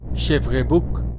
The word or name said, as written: Chévrebouc